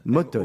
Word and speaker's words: Motown: Motown, prononce bien, s'il te plaît. Motown Oui, presque. Ah, vas-y, redis-nous. Motown. Motown. M-O-T-O-W-N, inspiré du fameux label euh, musical Motown.